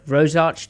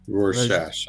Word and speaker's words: Rorschach: But before that, Griff, I feel like it's kind of now, how do I pronounce this word? Rosarch test? Rosarch test? – Rorschach, I think. – Rorschach. Rorschach. Rorschach.